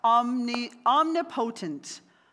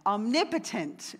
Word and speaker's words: omnipotent: The devil is not omnipotent. I always get this word wrong. Omnipotent is actually how you say it.